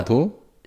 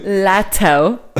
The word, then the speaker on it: Latto: Und da, Lado? – Ja. L-A-T-T-O. – Ach so, das ist ein bisschen serbisch geklungen, Lado. – Lado. – Lado. – Lato. Damit das T für den Kevin schön ausgesprochen wurde, ja.